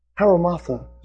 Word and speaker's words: hamartia: The Greek word for sin is a word, haramatha, is the way it's pronounced, as best I can tell. H-A-M-A-R-T-I-A.